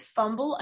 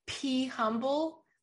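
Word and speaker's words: pHumble: It's Fumble. I thought it was P Humble. Like be humble, right? – Yes. Yes. – But you pronounce it Fumble. – You know what? They're both right.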